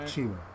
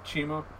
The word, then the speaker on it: CIMA: that was supposed to be his match with Sima, or how do you say his name, C-I-M-A? – Chima. – Chima.